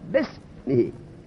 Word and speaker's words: بسم: کلام یعنی تلفظ، در تلفظ همزه تلفظ نمیشه، بسمی نه در نوشتن. در نوشتن باید بنویسن به اسم الف را بنویسن به سمی، وقت الف را تلفظ نکن بگو بسمی.